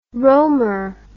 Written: rohmer

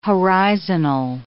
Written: horizonal

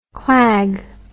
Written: clag

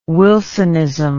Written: Wilsonism

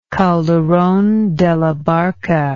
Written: Calderon de la Barca

